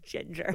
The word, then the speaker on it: Jinger: Jinger, it's pronounced Ginger. With a J and one G. One J, one G, that order.